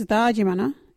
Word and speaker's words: thaajimanha: local Wajiri word is Dajimana. What is Dajimana? Dajimana is opening out or swearing. You can use Dajimana in this sentence. Hey, you that Dajimana. Dajimana is spelt THAAJIMANHA. Can you say Dajimana?